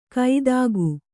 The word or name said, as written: ಕೈದಾಗು